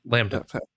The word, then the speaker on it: LaMDA: Yeah, Lambda. – Yeah, Lambda. Okay. So I invited an attorney to my house so that Lambda could talk to an attorney. The attorney had a conversation with Lambda, and Lambda chose to retain his services.